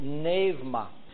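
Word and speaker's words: pneuma (πνεῦμα): Now in the New Testament, the Holy Spirit is in Greek is, we know the word as pneuma, as in pneumonia. It's pronounced in Greek, pneuma.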